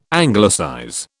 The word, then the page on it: Anglicize: British English